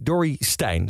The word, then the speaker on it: Dorrestein: Eh, oh, Becky Dory Stein, zo spreek je het uit.